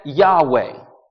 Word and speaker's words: Yahweh: In Latin, it's spelled YHWH. We pronounce that Yahweh.